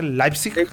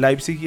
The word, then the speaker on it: Leipzig: Leipzig. Y van a jugar, y de ahí sale el rival de la llave de este partido que se definió hoy. O sea, PSG está esperando rival entre el Leipzig y el Atlético de Madrid.